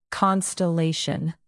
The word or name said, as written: constellation